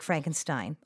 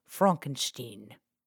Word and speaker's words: Frankenstein: Here's young Frederick Frankenstein, although he would prefer we pronounce it Frankenstein.